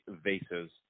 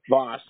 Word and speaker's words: vase: Yeah, it was one of those, I guess, lightweight vases. Voss, I don't know how to pronounce it. – Voss. – I'm not fancy, so I guess I'd just say vase.